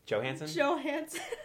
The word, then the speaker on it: Johansson: Oh, God forbid I say the J wrong. – Scarlett Johansson. If I said it with a J and there was no J, you'd be like, oh, you're so American. You hit it with a hard J.